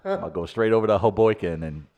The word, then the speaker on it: Hoboken: Say it like that. Hoboken.